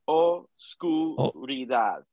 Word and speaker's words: Oscuridad: I'm so I'm just pronounce Oscuridad. – Oscuridad. Oscuridad. There we go.